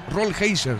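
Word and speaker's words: Rollheiser: El 32, Rolheiser. Está bien pronunciado.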